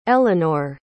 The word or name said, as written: elenor